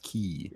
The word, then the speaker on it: Quay: It's pronounced Key.